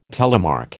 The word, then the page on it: telemark: American English